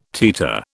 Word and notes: teeter: British English

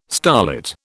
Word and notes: starlit: British English

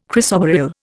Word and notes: chrysoberyl: Indian

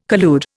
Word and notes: collude: Indian

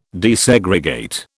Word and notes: desegregate: British English

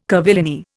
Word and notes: curvilinear: Indian

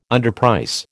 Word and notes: underprice: American English

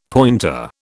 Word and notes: pointer: British English

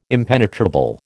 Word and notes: impenetrable: American English

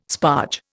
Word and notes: sparge: Indian

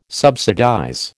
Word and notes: subsidize: American English

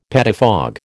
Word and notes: pettifog: American English

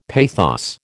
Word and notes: pathos: American English

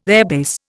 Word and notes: airbase: Indian

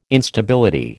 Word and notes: Instability: American English

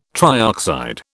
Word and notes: trioxide: British English

